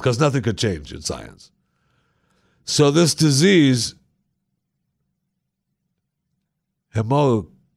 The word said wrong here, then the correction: hemochromatosis: Yeah, hemochromatose causes patients to... I know we have to say it right. All right, so this particular disease, this... – Hemochromatosis. – That's pretty close.